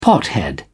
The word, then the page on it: pothead: British English